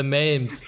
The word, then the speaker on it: meme: You say meme with a long E sound. It rhymes with memes. Team or beam.